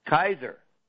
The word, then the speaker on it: Kaiser: K-A-I-S-E-R. – Kayser, K-A-I-S-E-R. That's usually pronounced Kayser.